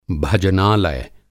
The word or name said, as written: भजनालय